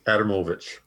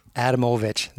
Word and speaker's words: Adamowicz: So pronounce it again. – Adamovich. – Adamovich. There you go. I should have made the O big, then I would have pronounced it right. Adamovich.